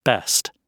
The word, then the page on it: best: British English